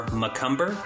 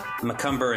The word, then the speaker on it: McCumber: They can find me on Twitter at Parker _ McCumber. McCumber is spelled McCumber.